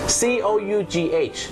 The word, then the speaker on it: cough: คุณผู้ชมครับภาษาอังกฤษเป็นภาษาที่บางครั้งเนี่ยนะครับการออกเสียงไม่แมทกับการสะกดอย่างแรงนะครับเช่นคำว่าอาการไอหรือมีการอาการไอนั่นเองครับ C O U G H ออกเสียงว่า cough cough นั่นเองนะ